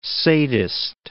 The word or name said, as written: sadist